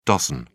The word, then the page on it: doz: British English